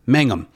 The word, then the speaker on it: Mangum: Let's make a deal, or uh, the current one, of course, with uh, Wayne Brady, and is it Jonathan Magnum? Is it Mangum? How, how do you say that? – Mangum.